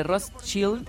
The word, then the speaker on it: Rothschild: Por ejemplo, la casa de Rothschild, los Illuminati y el club de Bilderberg.